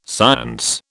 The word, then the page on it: science: British English